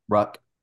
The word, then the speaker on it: Ruck: Ruck. Like Rook, but Rook.